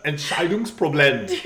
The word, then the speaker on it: Entscheidungsproblem: Entscheidungsproblem.